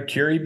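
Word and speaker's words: Kure: And he goes, oh, that's down near Curie Beach. And I said, well, you said it correctly. And he goes, well, yeah, a lot of people try and say it car, but that's the road in town.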